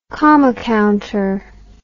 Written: comma counter

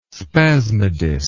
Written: spasmodist

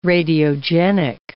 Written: radiogenic

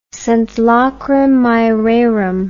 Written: sunt lacrimae rerum

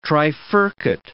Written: trifurcate

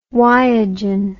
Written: yogen

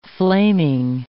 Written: flaming

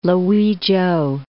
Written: Luichow

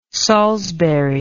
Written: salisbury

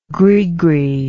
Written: grisgris

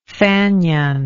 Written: fanion

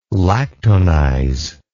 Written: lactonize

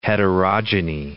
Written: heterogeny

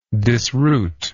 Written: disroot

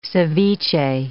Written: ceviche

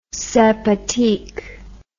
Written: sympathique

